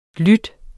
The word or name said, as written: lyd